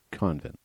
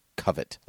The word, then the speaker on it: Covent: C-O-V-E-N-T? – It's not how you say it. Covent Garden. – Convent. – Okay. I'm telling you, that's like literally not how you say it. It's just like crate and crete. It's the same thing. – I'm not hearing a difference. – Okay. – Convent. – Nope. Covet. Like, almost like I covet this object.